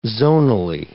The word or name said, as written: zonally